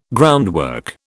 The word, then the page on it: groundwork: British English